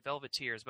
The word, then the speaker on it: Velveteers: that's how I pronounce it, Velveteers, but it looks like velvet ears, but it's one word.